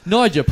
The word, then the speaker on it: Niger: You're very, N-I-G-E-R. – Ah. – Yeah, I believe I've spelled that right. – And how would you say that? – Niger? Yeah. Is that correct?